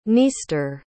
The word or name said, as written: dniester